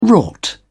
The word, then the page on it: wrought: British English